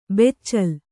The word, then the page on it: ಬೆಚ್ಚಲ್: beccal